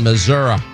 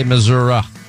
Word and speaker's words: Missouri: Let's say hi to Jack is in Missouri. Now, some people say Missouri, some people say Missouri. Anyway, well, how do you say it, Jack? – Missouri.